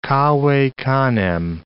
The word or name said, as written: cave canem